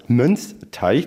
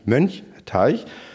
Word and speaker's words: Mönchteich: Der allererste Beitrag, den weiß ich noch ganz genau, da habe ich einen Beitrag über den Mönchsteich gemacht in Trittau ähm, der wird immer als Mönchsteich, deswegen habe ich es gerade so betont, ähm, ausgesprochen, aber heißt ja eigentlich Mönchteich.